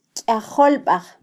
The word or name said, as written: kyajolbaj